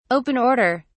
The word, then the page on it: open order: British English